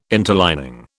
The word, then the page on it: interlining: British English